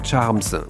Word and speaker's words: charms: C'est vrai que si on le prononce bien c'est un charms, voilà.